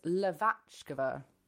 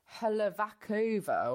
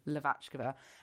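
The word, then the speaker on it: Hlaváčková: But yeah, it's Lavachkova, which it, a lot of people might look at her name and be like, Helavakova or something. But then I sort of tried to Google it and I think I'm saying it correctly, Lavachkova.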